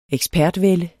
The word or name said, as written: ekspertvælde